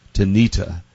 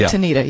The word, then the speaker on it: Tanita: Also, Tanisha, it's probably pronounced Tanita, who came out with those digital scales. – The scale people are Tanita, yeah.